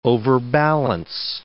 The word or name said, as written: overbalance